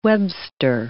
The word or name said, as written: Webster